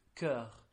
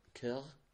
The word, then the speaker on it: cœur: Okay, so the word for heart in French is cœur. – Cœur? – Yeah, cœur.